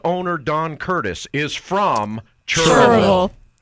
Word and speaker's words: Cherryville: So it's not Cherryville. – No. – People down here in North Carolina, we pronounce it Churrville.